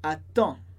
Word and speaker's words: attends: Então, no caso de attendre, fica attend. A gente não pronuncia nem o D, nem o S, mas a gente fala attend. E escreve, claro, com DS.